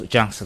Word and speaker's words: Junx: And the first one, how would you pronounce the title on this? Junks. – Junks, exactly. – J-U-N-X.